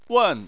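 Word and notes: wen: wen4; tone 4

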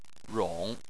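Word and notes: rong: tone 3

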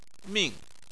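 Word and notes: ming4: tone 4

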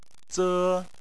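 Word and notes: ze: tone 1

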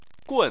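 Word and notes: gun: tone 4

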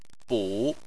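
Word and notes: bu: tone 3

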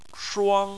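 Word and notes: shuang1: tone 1

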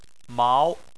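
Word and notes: mao2: tone 2

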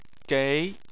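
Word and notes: gei: tone 2

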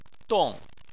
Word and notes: dong: 4

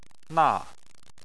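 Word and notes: na4: tone 4

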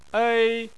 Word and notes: ei: 1

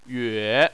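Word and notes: yue: tone 3

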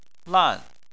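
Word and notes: lan: tone 4

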